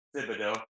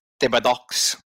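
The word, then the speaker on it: Thibodeaux: I don't believe that's how it's said. – No, it is. I saw you struggling. – Thank you. – The wheels were turning. – It's very Cajun. – Thibodeau. – He's a Cajun.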